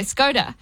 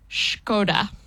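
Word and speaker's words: Škoda: People always say Skoda. – Yeah. – And I didn't know this. Apparently it's pronounced Skoda.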